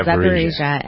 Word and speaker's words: Zaporizhzhia: Oh, Zaporizhzhia, yeah. – Is that how you pronounce it? Okay, yeah, Zaporizhzhia.